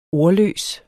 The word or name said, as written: ordløs